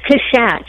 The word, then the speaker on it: Cushatt: Is it Cushatt? – It's Cushatt. It's French. You guys are Canadian. There's French people up there. You should know this. – I know. I put the wrong emphasis on the right syllable.